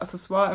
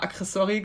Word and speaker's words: Accessoire: Zum Beispiel meine Cousine hat halt immer so anstatt Accessoire immer Accessory gesagt.